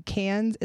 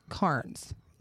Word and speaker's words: Cairns: I did learn how to say a lot of cities in Australia that I was saying wrong, including Cairns is not Carnes. – Oh. – Or okay. No. Yes, because I was saying it like a pirate.